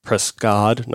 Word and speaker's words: Prescod: We ended up seeing this Prescod guy, Prescod, not Cott, as the subtitles tell me, it's spelled Prescod.